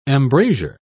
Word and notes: embrasure: British English

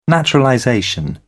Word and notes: naturalization: British English

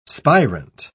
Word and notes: spirant: British English

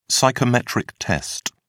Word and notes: psychometric test: British English

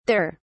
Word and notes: thur: British English

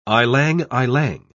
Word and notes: ylang-ylang: English; British English

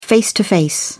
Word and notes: face to face: British English

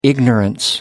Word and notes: ignorance: American English

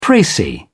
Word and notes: precis: British English

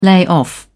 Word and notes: layoff: British English

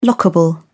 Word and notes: lockable: British English

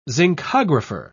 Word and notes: zincographer: British English

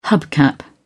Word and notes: hubcap: British English

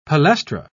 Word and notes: palestra: British English

